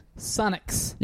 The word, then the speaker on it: Sunnucks: Brett Sunnux. N-U-S-U-N-N-U-K. Fuck, I can't even spell it. S-U-N-N-U-C-K-S. – Sunnux.